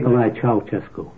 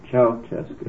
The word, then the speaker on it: Ceaușescu: Ceaușescu, is that how you pronounce it? – Nicolae Ceaușescu. – Ceaușescu. – Yes.